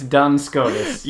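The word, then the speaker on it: Duns Scotus: Dun Scotus? – It's Dun Scotus.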